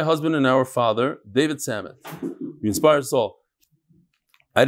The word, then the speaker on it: Samet: How do you pronounce it? Samet. No, in Hebrew it's Samet. It's Hebrew. Samet.